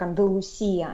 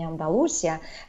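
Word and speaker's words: Andalucía: она произносится именно так, э, так же как Андалусия, а не Андалусия.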